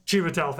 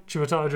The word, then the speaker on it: Chiwetel: Chiwetel, thank you. Chiwetel Ejiofor.